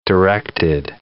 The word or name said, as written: directed